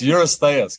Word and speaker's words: Eurystheus: Hephaestus, it's Eurystheus. Can you redo that? – Yeah, I put an extra syllable in there, sorry. – Yeah. Well, not that I heard it, but yes. – Let me just. Yuri's Thias. – No, there's no thigh in there that I know of.